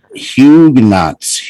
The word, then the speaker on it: Huguenots: And I just looked up the pronunciation of this. – Okay, yeah. – Huguenots. – Really? – I was right. – Where's the S coming from? – It's French.